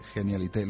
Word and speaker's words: Genialité: Hablamos de Genialité. ¿Lo he dicho bien, José Casquero? – Muy bien, sí.